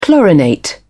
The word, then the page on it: chlorinate: British English